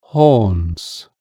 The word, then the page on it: horns: British English